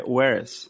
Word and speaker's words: whereas: palavra que sempre tem e que eu levei muito tempo pra entender é whereas. – Whereas. É, é que é? é whereas, né? Que se escreve. É, whereas. É, assim. Where, de onde, né? W-H-E-R-E. Uhum. E A-S. Whereas.